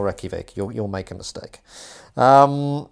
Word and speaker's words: Reykjavik: Have I pronounced that correctly? – Yeah, close enough. Reykjavik. – Oh, God, I'm under so much pressure. Reykjavik.